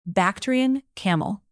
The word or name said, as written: bactrian camel